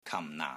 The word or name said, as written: KAM4 NAA4